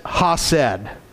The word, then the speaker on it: Hesed: But it would be, if we wrote it in English, it would be H-E-S-E-D, I believe is how we would spell that. And I think it would be, I would pronounce it as Hased. H-E-S-E-D, how I would pronounce it.